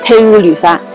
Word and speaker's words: 天雨流芳: 幕府迎宾门牌坊上有 4个大字，天雨流芳，意思是天降方雨润泽百姓。纳西语还有一层意思，意为读书去吧。 – 这就是东巴文的读书去吧，纳西语读作天雨流芳，听起来呢就像是汉语的天雨流芳。